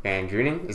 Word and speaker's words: VanDrunen: Today, I have a very special guest with me, Jack VanDrunen.